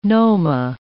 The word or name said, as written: noma